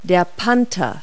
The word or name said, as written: panther